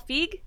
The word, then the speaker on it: Feig: This is a new movie by Paul Feig.